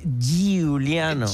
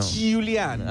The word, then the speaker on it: Giuliano: Pero lo tenés que pronunciar así, Giuliano. – Giuliano.